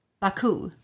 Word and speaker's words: Baku: Baku.